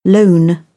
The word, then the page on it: loan: British English